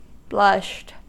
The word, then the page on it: blushed: American English